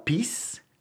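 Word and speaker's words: Pease: Se llaman Alan y Barbara Peace. Su apellido se escribe P-E-A-S-E, P-A-C. Alan y Barbara Peace.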